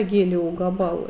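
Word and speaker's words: Гелиогабал: Гелио Габал, это так и читается.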